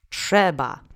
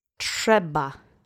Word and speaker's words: Trzeba: Trzeba. Accent is on the first syllable. Let's repeat three times. Trzeba. Trzeba. Trzeba.